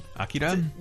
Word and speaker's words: Akira: Akira? – Yeah, more like Akira. Like less syllable.